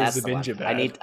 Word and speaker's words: Zibanejad: DJ Zibanejad. That's how I said it. – That's how you say it. You say Zibanejad.